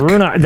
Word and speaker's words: Ruinach: No, there's no R. There's Ruinark. – Ruinark. – Ruinach. Ruinach? – Ruinach. That's how I remember them pronouncing it when we saw these things. Mm-hmm. But yeah, I don't know. Ruinach is what I would pronounce it based on how it's spelled.